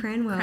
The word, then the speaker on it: Cranwell: They can find me on Instagram, Makayla Cranwell. – I've always wondered how to say your last name. – Cranwell.